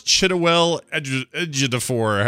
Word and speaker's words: Chiwetel Ejiofor: It is Chiwetel Ejiofor.